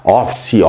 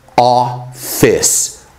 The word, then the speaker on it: office: É muito comum a gente ver as pessoas que estão começando pronunciar office, office, mas a pronúncia é office, office.